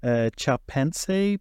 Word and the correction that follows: Chapultepec: Peace Accords. – Was it Chapultepec? – Chapultepec, that's it.